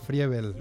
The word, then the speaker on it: Friebel: Normalmente dicen Friebes o Fiebrel. Pero no, lo has dicho bien porque es Friebel. Es una, Friebel. Una E larga, porque es alemán.